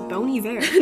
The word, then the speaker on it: Bon Iver: I used to think it was Bon Iver, but it is Bon Iver. – No, same. – Which seems weird. Bon Iver? – I know, right? What? – It's bony, B-O-N-Y, and then V-E-R, but it's... Yeah. But it's spelled B-O-N-I-V-E-R.